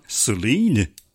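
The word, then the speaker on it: Celine: Et oui, en ouvrant Céline, faut le prononcer en anglais puisque c'est Céline sans accent aigu,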